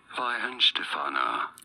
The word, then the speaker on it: Weihenstephaner: W-E-I-H-E-N-S-T-E-P-H-A-N-E-R. – P-H-A what? – N-E-R. – N-E-R.